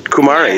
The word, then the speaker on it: Kumare: I'm talking to filmmaker Vikram Gandhi, who directed the film Kumare. Am I saying that right? – Kumare, yes.